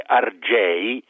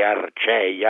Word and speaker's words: Argei: Intanto il nome Argei, che poi si poteva pronunciare anche Arcei, Archei, c'è questa alternanza nel latino arcaico fra la C e la G, Caius e Gaius, no? Che sono la stessa cosa.